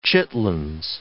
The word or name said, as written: chitterlings